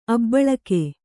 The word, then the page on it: ಅಭಿಲೇಖ: Kannada